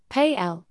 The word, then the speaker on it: Pelé: Arantes do Nascimento, born the 23rd of October 1940, known as Pelé, Portuguese pronunciation, Pay-L, is a Brazilian former professional footballer who played as a forward.